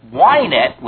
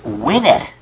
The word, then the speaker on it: Wynette: Incidentally, the correct pronunciation of the country singer Tammy's last name is not Wynette, which most people say, but she said it was Wynette.